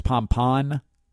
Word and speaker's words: pompon: P-O-M-P-O-N. We all say pom-pom, but it is pom-pon. Isn't that odd? Tell me you don't learn something on this show. Come on, give it up. Where else are you going to hear the correct pronunciation of pompon?